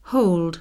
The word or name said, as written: hold